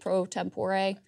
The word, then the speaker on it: pro tempore: Pro tempore? – Pro tempore.